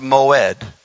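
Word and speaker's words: moed (מועד): The next word for seasons, it's 4150, and it's pronounced moed, M-O-W, I believe it's E-D, moed.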